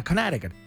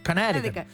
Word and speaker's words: Connecticut: Que se dice de otra manera en Estados Unidos, que es muy difícil, como que no pronuncian la C ni nada. Connecticut, Connecticut.